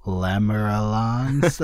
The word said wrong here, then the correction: Lamaleran: similar to my own name. – Yeah, yeah. The Lamalarans. – Lamalarans.